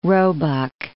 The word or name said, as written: roebuck